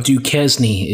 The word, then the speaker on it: Duquesne: Duquesne is the actual pronunciation, Ritter.